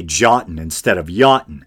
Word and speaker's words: Jotun: I mispronounced the word Jotun. A Jotun is a frost giant in Norse mythology, and the land where they dwell is Jotunheim. Now, I know that in Old Norse, German, Swedish, etc., the letter J is usually pronounced the way we pronounce the letter Y, as in, you know, yes or yellow.